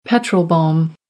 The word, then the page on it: petrol bomb: American English